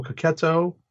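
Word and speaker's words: Checchetto: All right, next up is Daredevil number 26, written by Chip Zdarsky with art by Marco Cacchetto. I've heard that pronounced that way, so I'm going to adjust my pronunciation. The art is by Marco Cacchetto and Mike Hawthorne, with inks by Adriano Di Benedetto, colors by Marcio Meniz, and letters by VC's Clayton Cowles.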